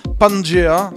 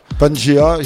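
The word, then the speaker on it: Pangaea: Et puis, euh, mon artiste favori du, favori du label SL Audio, du label anglais SL Audio, Pangea, ça s'écrit P-A-N-G-A-E-A, -E Fuzzy Logic, ça sort ça le 10 septembre, donc c'est une avant-première.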